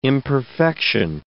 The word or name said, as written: imperfection